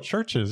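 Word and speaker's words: Chvrches: Oh, churches.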